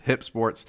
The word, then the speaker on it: HYP Sports: It's an app on your phone, HYP Sports, so hype without the E, but it is pronounced Hip Sports.